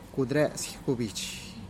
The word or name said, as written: kud res gubich